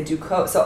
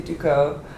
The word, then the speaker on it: Ducos: So if I say Duco, so all these thoughts are happening just in me being like, hi, my name's Lindsay Duco. And then I can see people's faces. I'm like, or Ducos. I just say it like that.